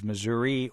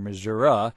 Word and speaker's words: Missouri: We thought it was Missouri or Missouri, depending on whether you were in the east or west of the state, but Mr. Gregory says it's more big city versus outstate.